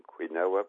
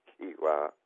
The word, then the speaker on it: quinoa: or it's written quinoa, but it's pronounced quinoa, into his daily diet.